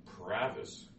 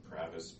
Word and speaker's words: Provis: Provis, Jr., and Provis, P as in Paul, R O V as in Victor, I S as in Sam.